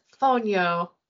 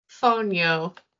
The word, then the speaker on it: fonio: You don't think Google says Fonio. Fonio. That's how they say it.